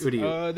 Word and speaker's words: Uryu: Uryu Ishida. – Uryu. That's Uryu.